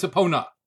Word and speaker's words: Epona: And we haven't even talked about Epony. – Yeah. – who is the It's not really how you pronounce her, right? – Is it not Epona? Oh, it's Epona. – Yeah, not a pony.